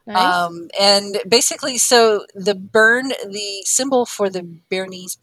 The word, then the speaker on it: Bern: Bern? – Bern. Yes, Bern.